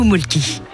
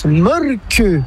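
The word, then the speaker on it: Mölkky: Ça s'écrit molki, mais ça se prononce molque. – Le molque. – Plutôt un E qu'un E, mais pas un U. Donc ça fait molque.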